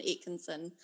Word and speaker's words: Aitchison: I've heard it all. Atkinson. It's like, it's pronounced exactly the way it's spelt, but still, I get Atkinson, Aitchison. Yeah, you did. Yes, you did pronounce it properly.